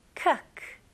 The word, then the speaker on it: cook: 여기서는 많은 분들이 cook이라고 발음하시죠? 아닙니다. 이거는 으 발음과 더 가깝고요. 그래서 cook, cook. 좋아요. 이거는 look, book, tuck. 다 똑같은 발음이에요.